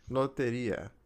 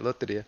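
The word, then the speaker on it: Lotería: Loteria. Am I saying it right? – Yeah, you got it. Loteria.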